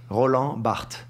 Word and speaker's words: Roland Barthes: Roland Barthes. – Barthes.